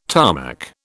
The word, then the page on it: Tarmac: British English